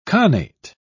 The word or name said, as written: connate